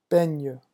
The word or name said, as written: beigne